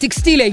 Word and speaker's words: текстиля: И с ударением на «и», и с акцентом на «я», но именно ударный слог с буквы «и» является верным. Поэтому правильно говорить «текстиля», Юрий.